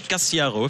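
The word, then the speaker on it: Casciaro: En défense, il y aura Ryan Cassiaro, c'est comme ça qu'il faut prononcer, ou Cassiaro, effectivement, à l'italienne, puisqu'ils ont des origines.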